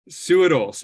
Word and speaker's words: Suhadolc: Suhadolc.